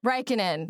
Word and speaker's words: Räikkönen: Räikkönen.